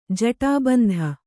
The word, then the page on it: ಜೂಟಬಂಧ: jūṭa bandha